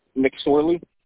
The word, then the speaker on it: McSorley: McSorley. – Please spell that. – M-C, capital S-O-R-L-E-Y. – O-L? – O-R-L-E-Y. – L-E-Y.